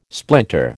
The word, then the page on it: splinter: American English